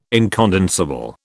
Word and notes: incondensable: British English

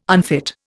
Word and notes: unfit: Indian

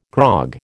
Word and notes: grog: American English

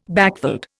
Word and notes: backveld: Indian

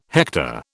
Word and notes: hector: British English

